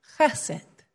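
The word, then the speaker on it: Chesed (חֶסֶד): Chesed. Chesed. We don't have that ch sound in English, but it's chesed.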